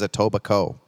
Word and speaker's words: Etobicoke: How do you pronounce Etobicoke? Darren Slade's checking in. The K is silent, so it is Etobicoke.